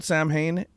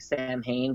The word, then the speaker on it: Samhain: So, yeah, as everybody knows, it looks like Samhain, but it's actually pronounced Samhain, believe it or not.